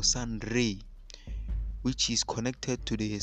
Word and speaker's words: Ra: The word Ra is the name of an ancient god called Ra, pronounced Re.